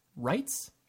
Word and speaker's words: Reitz: Reitz? – You got it.